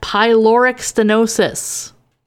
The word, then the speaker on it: pyloric stenosis: The condition that Klebold was born with is pronounced pyloric stenosis.